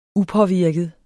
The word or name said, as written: upåvirket